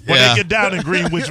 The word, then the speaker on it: Greenwich: Wait, is that how you say it? Greenwich Village? – Yeah. – Sure. – Greenwich? – You got it.